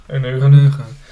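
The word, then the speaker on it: eunuchen: Ehm, ja, ja corruptie in het systeem, uh, de, de Euneugen, dus, eh, hoe spreek je dat uit? – Euneug. – Euneugen. Het Hof, eh, de Euneugen, die krijgen steeds meer macht, dus dat zijn soort...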